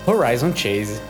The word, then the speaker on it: Horizon Chase: Horizon Chase.